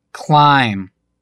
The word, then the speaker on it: climb: Буква «Б», стоящая после буквы «М» в конце слова, не произносится. Послушайте примеры. – Jam. I am. Lamb. Limb. Dithram. – Bomb. Comb. Dumb. Numb. Thumb. – Plum. Climb.